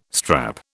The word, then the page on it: strap: British English